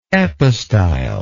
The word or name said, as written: epistyle